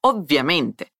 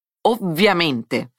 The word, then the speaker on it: ovviamente: Poi, attenzione alla parola ovviamente. Diversa dal portoghese, qui cambiamo la B con una doppia V. Ovviamente. Fai attenzione alla pronuncia della doppia consonante.